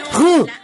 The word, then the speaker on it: Renault: La R que es un raspón de garganta. Después tiene acento y además se ubica al fin de sílaba, entonces es E y se pronuncia R. Después combinación de la A, U, que se pronuncia U en francés y tenemos Renault.